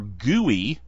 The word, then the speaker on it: GUI: A GUI, or GUI, as it's sometimes pronounced, is a graphical user interface.